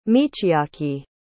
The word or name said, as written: michiaki